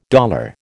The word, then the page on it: dollar: American English